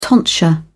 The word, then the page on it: tonsure: British English